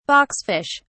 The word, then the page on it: boxfish: British English